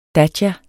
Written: datja

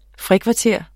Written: frikvarter

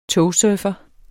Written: togsurfer

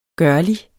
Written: gørlig

